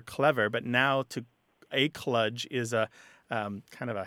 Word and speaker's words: kludge: And then the other word, just to dispense with that pretty quick, kludge is how it's often said, though kludge is how it looks like it's spelled, K-L-U-D-G-E,